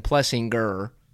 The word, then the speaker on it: Plessinger: And he pretty much was like, well, my whole life has been Plessinger, pronounced like the hard G sound, but he pretty much was like, people call me whatever.